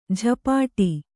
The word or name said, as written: ಝಪಾಟಿ